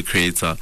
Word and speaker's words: Kreator: Uh, so all my um, social media platforms have the same name, Sabelo the Kreator.